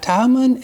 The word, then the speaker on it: Timon: and Timon, that's a long I, Timon, and Parmenas, I didn't pay attention to these words, and Nicholas, a proselyte of Antioch.